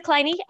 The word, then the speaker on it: Kleine: My name is Jessica Kleine, and you pronounce the E on my name, which nobody does.